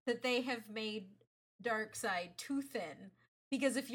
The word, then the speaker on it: Darkseid: The correct answer is Darkseid. – This feels like a very Hunter kind of thing here. – No, it's not. Panels say Darkseid. There is literal audio evidence of how you pronounce it.